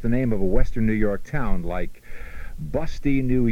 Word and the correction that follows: Busti: B-U-S-T-I is pronounced Bust-I.